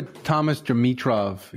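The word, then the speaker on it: Thomas Dimitroff: The one thing I'll say is, if you haven't ever listened to it, go to Thomas Dimitrov.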